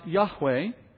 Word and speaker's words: Yahweh: It became YHWH, which we sometimes pronounce Yahweh.